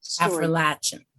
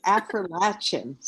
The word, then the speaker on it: Affrilachian: Afrolatian. – Oh, I was going to ask you how you say it. Afrolatian storyteller.